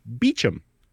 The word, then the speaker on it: Beauchamp: And apparently in the UK, it's pronounced Beecham.